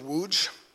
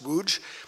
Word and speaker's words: Łódź: We did, a big part of our ministry was working on the college campus in the city of Łódź. It's spelled L-O-D-Z, but it's pronounced Łódź, because that's how Polish works.